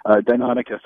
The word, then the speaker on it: Deinonychus: Correct me on the pronunciation, Deinonychus? – Yeah. There's a couple of variations, but that was the inspiration for Velociraptor in Jurassic Park. So Deinonychus, I guess, is the way that I usually go with it.